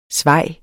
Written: svaj

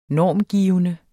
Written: normgivende

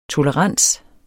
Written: tolerans

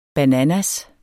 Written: bananas

